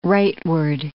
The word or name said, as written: rightward